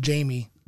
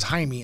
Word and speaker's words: Jaime: It's pronounced Jamie in American. Right. It's Jaime in Spanish.